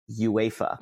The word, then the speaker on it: UEFA: UEFA is actually pronounced UEFA. – Ah, okay. – It's actually treated as if it's a word.